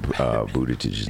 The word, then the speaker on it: Buttigieg: Dennis is not here and he stepped out, but he always corrects me on Buttigieg's name.